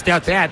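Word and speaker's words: Tete: Por cierto, para confirmar pronunciación, Teate, ¿cómo es? – Yo creo que es Teat. – Yo creo que es Teat. – Teat. Correcto.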